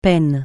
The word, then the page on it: peine: francais